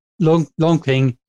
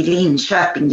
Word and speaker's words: Linköping: But the correct Swedish pronunciation would be Linköping University. – Linköping.